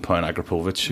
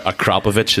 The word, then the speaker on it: Akrapovic: Then I went a bit crazy and stuck a £7,500 Akrapovic exhaust on it. Or sorry, Akrapovic is the proper way to say it.